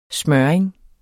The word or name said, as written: smøring